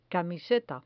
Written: camiseta

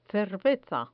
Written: cerveza

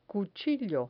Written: cuchillo

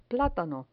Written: platano

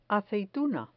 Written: aceituna

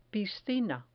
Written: piscina

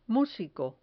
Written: musico

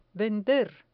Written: vender

